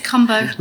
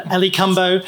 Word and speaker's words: Cumbo: All right, lean into it, it's Cumbo. – Ellie Cumbo. – Just like Dumbo, we'll see.